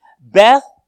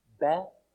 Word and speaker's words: Bethsaida: He was born in Bethsaida, Now, the English translation is Bethsaida, and we say that most often. But say this with me. Bethsaida. Bethsaida. The emphasis is on the da.